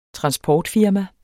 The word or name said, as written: transportfirma